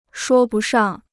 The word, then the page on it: 说不上: shuō bu shàng